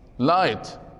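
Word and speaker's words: light: طيب الآن كيف تنطقون هذه الكلمة؟ لايت، أين الجي؟ سايلنت، ليست هنا، خرجت من اللعبة، مع أن جي اتش كجي اتش التي مضت.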